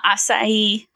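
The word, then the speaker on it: açaí: Acai, say it. It's Portuguese.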